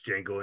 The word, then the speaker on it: Django: It's Django Unchained. – Hell yeah. – Our third nominated line. The D is silent.